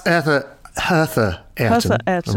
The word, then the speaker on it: Hertha Ayrton: So that's Hertha Ayrton.